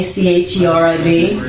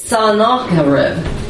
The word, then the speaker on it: Sennacherib: You know, they look like what we think they're supposed to be, like king that of Assyria, Senachrib. Sennacherib? No. It's Sennacherib.